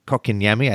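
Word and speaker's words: Kotkaniemi: Paul Byron, Philip Deneau, I'm going to butcher his name, Kokiniemi.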